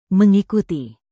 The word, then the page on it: mengikuti: indonesian